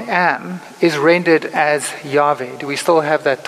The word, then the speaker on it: Yahweh: And it's often pronounced by us as Yahweh. You've heard that. We sing songs like that, Yahweh.